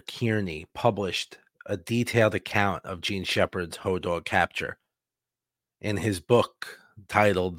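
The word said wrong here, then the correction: Hodag: Ho-Dag. Why do I keep saying dog? I keep pronouncing it wrong.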